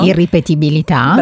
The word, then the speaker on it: irripetibilità: irreperibilità. L'ho detto giusto? – Irripetibilità. – Bravissima.